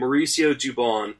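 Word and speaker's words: Mauricio Dubón: In the Travis Shaw and Tyler Thornburg trade, a prospect gets moved named Mauricio Dubon. Did I say that right? – Got it. – Yep. That's correct.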